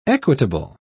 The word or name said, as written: equitable